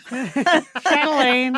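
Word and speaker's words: Châtelaine: Am I pronouncing that correctly? – Because you could just say Châtelain. – Châtelain. – Châtelain. – Well, so you're Canadian. That's the official pronunciation? – Yes.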